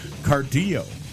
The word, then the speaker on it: Cardillo: I'm my last name, Cardillo. – I thought that was Italian. – Cardillo is Italian, but everybody pronounces it like it's Spanish. Cardillo. I'm Robert Cardillo.